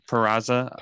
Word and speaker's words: Peraza: Peraza.